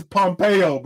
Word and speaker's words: Pompeo: It's Pompeo, bro.